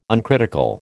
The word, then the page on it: uncritical: American English